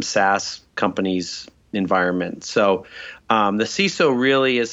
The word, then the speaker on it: CISO: So the CISO is usually how CISO is pronounced.